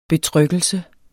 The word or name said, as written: betryggelse